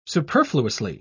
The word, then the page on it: superfluously: British English